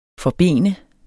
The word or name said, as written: forben